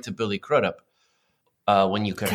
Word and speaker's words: Crudup: Billy, how do you pronounce his last name? Crudup? – Billy Crudup? Oh, yeah, yes. Billy Crudup.